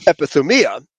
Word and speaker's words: epithumia: So now, but here the lust is still epithumia, or actually it says epithumia is the pronoun, the accent on the second last syllable, epithumia.